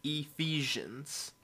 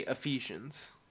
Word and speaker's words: Ephesians: Well, how do you pronounce that again? – Ephesians. But people normally say Ephesians.